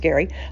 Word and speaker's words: Gerry: Gary, G-E-R-R-Y, but it's pronounced Gary, writes for Splash Magazine Worldwide and All Things Cruise.